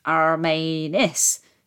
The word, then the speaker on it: Romanes: So David Romanes, pronounce Armanis, second syllable emphasis, there we go, if you're wondering, although we say it three different ways within my own family.